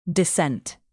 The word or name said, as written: descent